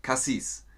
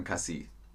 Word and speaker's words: Chassis: Das Fahrwerk heißt in der Fachsprache übrigens Cassis oder Chassis, wie auch immer man das aussprechen möchte. Die Deutschen sagen Cassis.